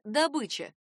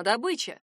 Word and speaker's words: добыча: Похожее существительное — добыча. Для обычных носителей русского языка она — добыча.